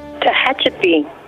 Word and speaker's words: Tehachapi: Tehachapi, how do you say that in California, that town? – Tehachapi.